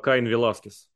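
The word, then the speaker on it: Caín Velásquez: На мексиканском правильно говорить Каин, Ганс... Э, Каин Веласкес.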